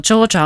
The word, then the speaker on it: Georgia: Joo, Georgia on oikeastaan se ääntämistapa, jota suositellaan.